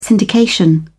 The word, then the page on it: syndication: British English